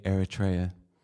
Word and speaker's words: Eritrea: AKA Eritrea. – Just to let you know, I am pronouncing it the way the natives are pronouncing it.